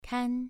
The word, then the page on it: kan: 2